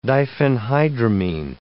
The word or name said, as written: diphenhydramine